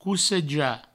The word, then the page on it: cossejar: cousseja